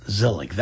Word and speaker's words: Zelig: Yes, Zelig. That spelled like Woody Allen Zelig.